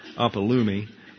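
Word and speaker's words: Apolumi (ἀπόλλυμι): We have apate, we have adikia, and down at the bottom we have apolumi. Apolumi is the correct pronunciation.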